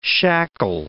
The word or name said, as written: shackle